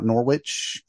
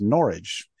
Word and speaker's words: Norwich: By the way, when you see it spelled Norwich, it is not Norwich. It is Norwich. I know this because I got corrected when I lived there. So, you know, I was told it's pronounced like it's porridge.